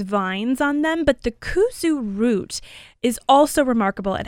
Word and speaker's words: kudzu: Another thing to consider is kuzu powder. And kuzu is spelled K-U-D-Z-U, sometimes just K-U-Z-U.